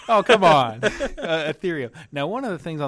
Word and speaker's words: Ethereum: You could give yourself as much urethium as you wanted. – Oh, Ethereum. – Or ether, whatever it is. Ethereum. I don't know how to say the word. – It's Ethereum. It's not a urinary tract.